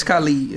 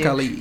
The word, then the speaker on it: Khalid: I mean, some say Khalid, some say Khalid. – It's Khalid. It's pronounced Khalid.